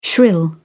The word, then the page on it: shrill: British English